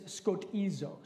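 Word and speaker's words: skotizo (σκοτίζω): The Greek is skotizo. Skotizo is how you pronounce that.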